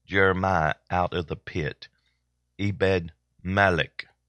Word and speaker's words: Ebed-Melech: I knew I would say it wrong. It's Ebed-Melech. Ebed-Melech. Here, let's listen to it again. – Ebed-Melech.